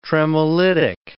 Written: tremolitic

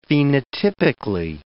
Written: phenotypically

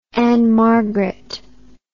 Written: ann margret

